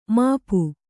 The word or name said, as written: ಮುಪ್ಪು